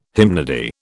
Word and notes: hymnody: British English

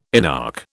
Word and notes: inarch: British English

